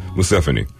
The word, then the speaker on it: Museveni: the former president or the current president I'll talk about this more later, but President Museveni. – Mm-hmm. Let me make sure I'm saying that right before we go any further. Yeah, Museveni.